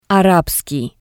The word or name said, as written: Arabskii